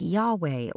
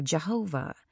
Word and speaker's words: Yahweh: When you see all caps L-O-R-D, it represents an ancient Hebrew spelling of Y-H-W-H. It has no vowels, so when we try to pronounce it, it sounds like Yahweh or Jehovah.